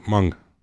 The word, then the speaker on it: Hmong: That's just the way they pronounce it. Hmong. It's more like there's no H either.